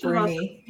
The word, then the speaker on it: Brunne: It's Brunne.